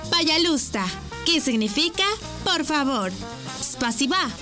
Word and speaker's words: пожалуйста: Пожалуйста. ¿Cómo? Пожалуйста. Una vez más. Пожалуйста. Y se escribe Payalusta. P-A-Y-A-L-U-S-T-A.